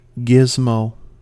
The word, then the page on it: gizmo: American English